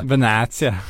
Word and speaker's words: Benatia: Ja, det, eh, det kom ju också rykten om eh, Benatia eller som eh, han egentligen, Benatia Benatia eh, Som det ska uttalas tydligen eh,